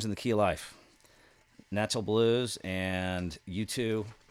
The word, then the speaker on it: Nacho: Taj Mahal, how do you say this? Nacho Blues? – Nacho Blues. – So almost like nacho.